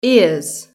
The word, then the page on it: ears: British English